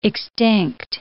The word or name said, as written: extinct